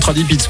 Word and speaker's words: Tradipiz: C'est T-R-A-D-I-P-I-Z. P-I-2-N. – Ah bah comme Tradipits quoi. C'est... – Voilà. – comme ça se prononce.